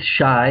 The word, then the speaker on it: Shai: Oh, my name is Shai. It's spelled S-H-A-I, uh-huh.